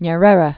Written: Nyerere

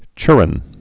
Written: chirren

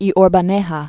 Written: Rivera y Orbaneja, Miguel Primo de

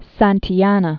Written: Santayana

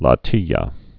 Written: latilla

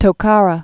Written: Tokara Islands

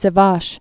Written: Sivash Sea

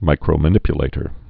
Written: micromanipulator

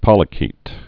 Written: polychaete

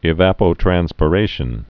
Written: evapotranspirations

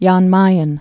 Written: Jan Mayen Island

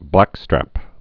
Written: blackstrap

